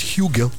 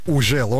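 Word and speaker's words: Hugel: Трек называется «Ужел», а кто-то говорит «Хюгел». – Да как мы его только не называли в свое время. «Ужел», «Хюгел», да? «Хюгель». – Самое правильное произношение – это «Ужел». Он француз.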